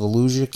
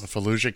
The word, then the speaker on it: Volusia: At a Saturday block party in DeLand, Florida, that drew an estimated 3,000 people, according to the Volusia, that's how that's pronounced, Volusia County Sheriff's Office. – Volusia County, yes.